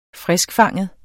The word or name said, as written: friskfanget